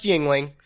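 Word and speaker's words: Yuengling: It is appropriate that you're drinking a beer called Youngling. – it's pronounced Yingling, but yes, Younglings.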